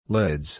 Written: lids

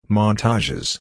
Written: montages

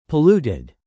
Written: polluted

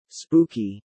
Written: Spooky

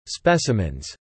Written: specimens

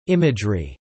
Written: imagery

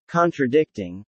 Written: contradicting

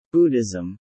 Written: Buddhism

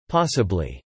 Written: possibly

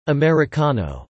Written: Americano

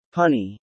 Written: punny

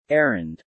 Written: errand

